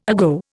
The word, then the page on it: ago: Indian